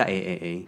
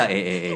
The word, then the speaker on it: AAA: Triple A，你们到底有怎么念它？ – 我们就叫 A A A。 – 好，没错，它就叫 A A A。